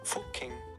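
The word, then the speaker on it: Fucking: Fuking. – That's the official pronunciation.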